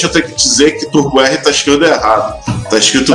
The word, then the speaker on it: Turbo R: Turbo R, como diz no manual. – Turbo é. R. É, é o, que o manual diz a pronúncia certa em japonês.